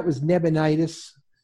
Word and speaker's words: Nabonidus: His father was king, and it really only a couple of years after the king became, and that was Nabonidus, you got his name right.